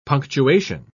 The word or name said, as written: punctuation